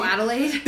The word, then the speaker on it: Adelaide: Oh, Adelaide.